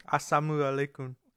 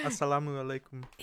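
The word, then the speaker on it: Assalamu alaikum (السلام عليكم): Assalamu alaikum. – Oh boy, look at you. – Wait, I think I said that wrong. – Yeah. – Assalamu alaikum.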